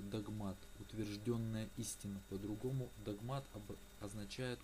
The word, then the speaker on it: догмат: В слове догмат ударение идет на второй слог, на букву А, а не догмат.